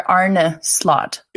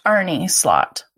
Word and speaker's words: Arne Slot: I think, though, I've listened to a number of podcasts and then hearing you pronounce his name, you're saying more Arne Slot, right? – It is. I read a story about it this morning. – Okay, and I've also heard it pronounced Arnie Slot.